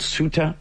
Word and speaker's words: Ceuta: Let's go to a place called Ceuta. At least that's how it says it's pronounced in the United States. It is spelled C-E-U-T-A. It's Spanish territory.